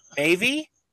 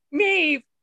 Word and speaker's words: Maeve: Number two is Mavy. Is that right? Mavy? – No? Mavis? – No. It's me. Mave. – Mave. – Mave. – Okay. – M A E V E. And I have heard it pronounced Mave.